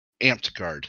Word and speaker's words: Amtgard: Amped Guard. It's spelled AMTGARD.